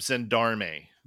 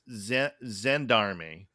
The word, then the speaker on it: gendarme: No, I had to look up Gendarme. – Gendarme? – Yes. And I think it's pronounced Zendarme because it's a armed police officer in France and other French speaking countries.